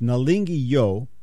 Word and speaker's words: Nalingi Yo: That was Nalinga Yo. It was pronounced wrong here. It's Nalingi Yo Lingangai,